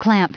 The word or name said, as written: clamp